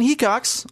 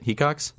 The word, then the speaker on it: Hecox: Yeah, and that's why we have Ian Hecox on the podcast. That's how you say it, right? Hecox? – You said it right.